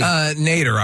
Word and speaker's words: nadir: Did I say Nadir correctly? – Nader, I always say. – Okay. – But you could be right. I've always just pronounced it Nader.